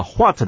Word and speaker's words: what: 里面这个 what 的 H 啊不发音，所以只念 what。